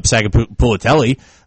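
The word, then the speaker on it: Sagapolutele: Bailey shouldn't be where they're at, or Sagapulitelli. I think I said the name right. I mean, yeah, very well done.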